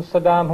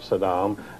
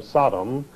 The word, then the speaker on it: Saddam: Well, George was always a business partner of Saddam, and he claimed he didn't know him, so he deliberately, when he was on camera, he always mispronounced his name. He always called him Sodom, as though he were trying to call him a sodomite.